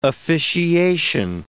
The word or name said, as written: officiation